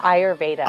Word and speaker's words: Ayurveda: Yeah, Ayurveda.